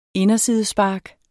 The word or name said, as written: indersidespark